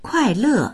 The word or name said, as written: kuai4le4